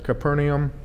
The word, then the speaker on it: Capernaum: The point is that the cities Bethsaida, Capernaum, and it's actually Capernaum, they pronounce every two letters, okay, but it's really hard for me to say, in Chorazin, where Jesus taught and ministered, were responsible.